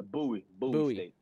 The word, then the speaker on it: Bowie State: And is it pronounced, correct me if I'm wrong, is it Bowie State or? – Oh, yeah, Bowie State. – Bowie State, yeah.